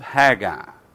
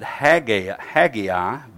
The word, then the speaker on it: Haggai: The book of Haggai. You may pronounce it Haggai, but it's Haggai.